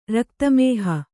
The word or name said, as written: ರಕ್ತಮೇಹ